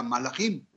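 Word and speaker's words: malajim (מלאכים): Pues bien, resulta ser que ángel en hebreo se pronuncia malaj de manera singular, mientras que de manera plural se pronuncia malajim,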